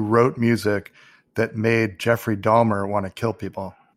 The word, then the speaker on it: Dahmer: But it's pronounced Dahmer. – No, it's Dahmer. – Yes, Dahmer. That's how it should sound.